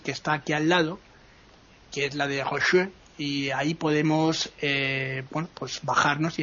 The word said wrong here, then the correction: Denfert-Rochereau: Hay que también tener en cuenta que la estación, no te lo he dicho mal, ¿no? Es Denfert-Rochereau, ¿no? Que es como se llama.